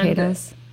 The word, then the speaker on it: cicadas: Is it cicadas? Is that how you say it? – Yeah, cicadas.